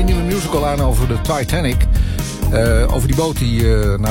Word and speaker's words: Titanic: Nou, het is Titanic. Het is zeg maar hoe Celine het op zijn Engels met haar Franse accent zou zeggen. Dus Titanic.